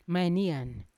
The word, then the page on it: mainian: Yawa